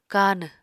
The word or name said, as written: kanw